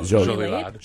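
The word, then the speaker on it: Jolie-Laide: How do you say it? – Oh, Jolelade. – Jolelade. Jolelade, yeah.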